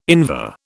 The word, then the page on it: Invar: British English